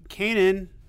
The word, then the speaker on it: Kanan: Another thing we love seeing is a new issue of Kanan, number 9. Is that the right pronunciation, Tucker? – Yeah.